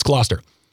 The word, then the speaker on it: Gloucester: I usually know how to say these names. Gloucester. It's Gloucester.